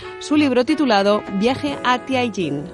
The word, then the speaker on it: Tianjin: Tianjin, que significa vado, vado celeste o algo así, un paso hacia el cielo, paso del emperador, también puede traducirse.